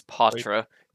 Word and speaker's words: Poitras: Poitras? – I believe it's Patra, because the Bruins posted a video, like, it was of him saying it, and I heard him say his last name.